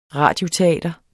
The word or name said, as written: RADIOTEATRET